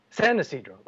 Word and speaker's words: San Isidro: Is that how you say it? Isidro? – San Isidro.